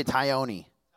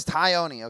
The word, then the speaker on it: Tognoni: Everybody say Tione. It's like pepperoni, but with like a tie instead. That doesn't make sense. But it's Tione, okay? It's spelled Tognoni.